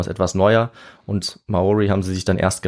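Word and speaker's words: Māori: Man nennt sie auf Deutsch Maori. – Ah, ja. – Oder in deren Sprache spricht man es ein bisschen anders aus, eher so Maori oder Maori.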